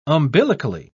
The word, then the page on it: umbilically: British English